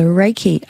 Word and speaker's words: Reiki: So Reiki, R-E-I-K-I, is a Japanese energy healing technique. Mm. And so Rei is universal or spirit wisdom, and the Ki is the life force energy, which is apparently the same concept as... is it spout or pronounced the same way? Qi, qi, the Chinese in Chinese medicine.